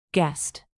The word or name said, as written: guessed